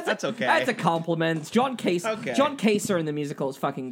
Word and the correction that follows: Joan Cusack: Joan Cusack.